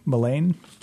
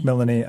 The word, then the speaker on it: Melanie: Let's see if I pronounce her name right. Melaine? – Melanie.